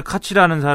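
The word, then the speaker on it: Koch: 이때 에드 카치라는 사람이 이제 출마를 했는데 KO, 카치. 어, KOCH죠. 네, 그래서 카치인지 코큰지 카치라고 코인지. 읽습니다. 뭘 뭐라고 읽어야 되는 지 미국인들은 그렇게 읽으니까요.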